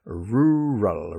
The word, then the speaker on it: rural: So that word really, really, and that difficult word I mentioned, rural, r-u-r-a-l, two syllables, r-u, ru, and rural. Okay, good.